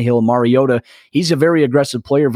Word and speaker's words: Mariota: And you have made yourself a little bit of headway with the Titans fan base by pronouncing Mariota right. You know, that's pretty important. Mariota is the pronunciations of the name go back and forth, but when you pronunciate it right, the fans really appreciate that.